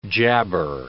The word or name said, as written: jabber